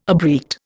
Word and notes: abreact: Indian

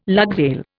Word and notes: lugsail: Indian

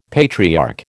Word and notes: patriarch: American English

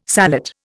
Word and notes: sallet: Indian

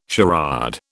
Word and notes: charade: British English

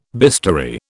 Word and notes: bistoury: British English

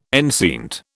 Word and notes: enceinte: British English

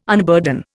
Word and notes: unburden: Indian

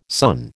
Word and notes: son: American English

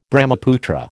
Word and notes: Brahmaputra: American English

